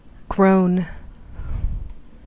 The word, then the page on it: groan: American English